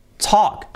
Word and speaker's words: talk: Ahí tenemos una L que vais a decir, la L se pronuncia talk, talk, es una O, una O. Bueno, sobre todo lo importante es que la L fuera y es talk, cha, cha, con la T muy fuerte, talk.